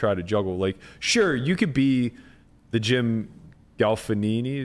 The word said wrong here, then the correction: Gaffigan: Is that how you pronounce it? – Is that the it? – Guy with the puppets? – No. – God, I hate that guy. – Jim Gaffigan? Tim Fader? – Gaffigan, yeah. Where he's just like, he's super PG.